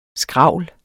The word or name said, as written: SKRAVLENE